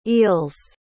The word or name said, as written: eels